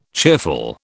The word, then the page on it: cheerful: British English